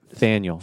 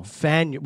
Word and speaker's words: Faneuil: How do you say that? – Faneuil. – Faneuil, with an F.